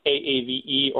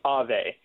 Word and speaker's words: AAVE: You might call it AAVE, or some people pronounce it as AVE.